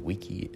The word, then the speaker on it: vici: In Latin, it's actually vici, it's hard C.